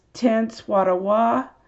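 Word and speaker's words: Tenskwatawa: So there's the Anna the prophetess, to Ken Swatawa, the prophet connection. I think I mispronounced his name, but again, Tecumseh's younger brother is Ten Swatawa,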